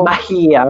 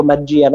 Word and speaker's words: magia: Mahia, ho detto proprio, non ho detto magia, no? Perché lo direbbero tutti i pisquani, magia, io ho detto Mahia proprio.